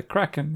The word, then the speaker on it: Kraken: Did I pronounce it well? – Yeah, the Kraken, yeah.